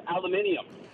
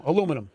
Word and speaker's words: aluminium: They pronounce aluminum aluminium. – Yeah. – That's what the Brits do. – Aluminium. But aluminium. – The Jaguar. Yeah, exactly, yeah. No, aluminum.